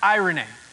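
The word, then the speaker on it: irene (εἰρήνη): The word that's used here for peace is this word, and it's pronounced irene.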